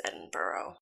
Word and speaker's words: Edinburgh: Um, it's spelled weird, but I, it is Edinburgh.